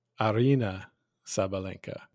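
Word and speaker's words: Aryna Sabalenka: Well, Sean, I am familiar in the sense that I know how to pronounce her name, Arina Sabalenka.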